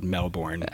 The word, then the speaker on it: Melbourne: I appreciate that you corrected your Australian pronunciation of Melbourne. – I know, it's Melbourne.